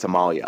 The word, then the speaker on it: Amalia: And Holland is one of them, and they have this Dutch princess, Amelia. I hope I'm saying it right. No, I think it's Amalia. Amalia.